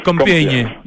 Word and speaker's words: Compiègne: Compiègne, ¿se pronuncia? – Compiègne,